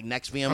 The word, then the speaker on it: NXIVM: It's like next. That's how it's like NXIVM.